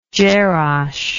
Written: jerash

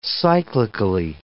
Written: cyclically

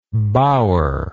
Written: bauer

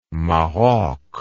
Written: Maroc

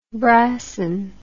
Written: brassin

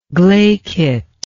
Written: glaikitness

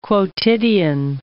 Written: quotidian

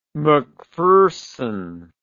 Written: McPherson

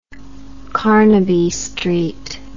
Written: carnaby street

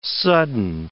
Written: sudden

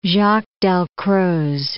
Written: Jaques-Dalcroze

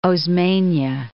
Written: Osmena

